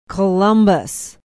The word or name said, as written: Columbus